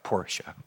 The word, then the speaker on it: Porsche: You got to say it right, Porsche.